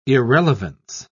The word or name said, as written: irrelevance